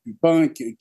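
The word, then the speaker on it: Puppinck: C'est ainsi que le nom est fait pour être prononcé. Mais en France, on le prononce souvent Pupinck. Moi, j'ai une préférence pour Inc, c'est-à-dire que c'est la prononciation originale du nom, même si l'usage en France est plutôt répandu de dire Pupinck. Donc les deux peuvent se dire.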